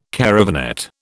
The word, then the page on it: caravanette: British English